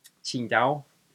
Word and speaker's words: Tsingtao: Tsingtao is how it's supposed to be pronounced.